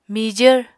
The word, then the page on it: mijvr: Apatani